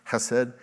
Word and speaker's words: chesed: In the Bible, the Hebrew word for mercy is chesed. It's spelled like C-H, but it's pronounced with that Jewish ch sound, chesed.